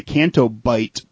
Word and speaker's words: Canto Bight: Next vehicle is the Canto Bright police. I'm sorry, I'm saying this wrong. It's the Canto Bight police speeder.